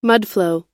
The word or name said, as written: mudflow